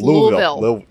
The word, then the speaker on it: Louisville: Louisville. Dave, it's Louisville.